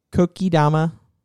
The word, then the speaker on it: Kokedama: Coming up also on Thursday at 6:30 at Groovy Plants Ranch is Kokodama Moss Ball Art. – Ooh, I think I pronounced that right. Kokedama. Kokodama, I think is what it is.